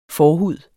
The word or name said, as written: forhud